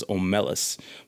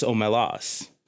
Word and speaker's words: Omelas: And I want to ask y'all a question because I thought the pronunciation was Omelas, but apparently it's Omelas.